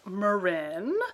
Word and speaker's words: Marin: It is pronounced Marin, meaning ocean, of course, or sea, or something like that.